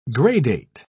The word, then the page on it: gradate: British English